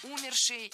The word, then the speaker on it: умерший: Умерший, неверно. Ударение в этом слове должно падать на корень слова «умерший». Это, пожалуй, самое проблемное причастие с точки зрения ударения. В нем очень часто делают ошибку. Его надо просто запомнить «умерший».